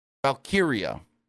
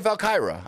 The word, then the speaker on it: Valkyria: Lynch knew that Lyra Valkyrie, I always butcher her last name. Lyra Valkyria. For some reason, I just want to say Lyra Valkyra. I just want to rhyme it.